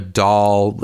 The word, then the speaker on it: dal: First thing, just so you know, a dal, and that's spelled D-A-L. Sometimes you'll see them spelled D-A-H-L or D-A-A-L.